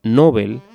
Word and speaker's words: Nobel: Yo me apego a la forma llana, Nobel, tanto por la fuerza de la costumbre como por el hecho de que así evitamos confundirla con novel.